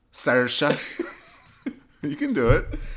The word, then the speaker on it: Saoirse: Saoirse Ronan? – Yeah, in part. – Is that how we say her name? – Saoirse.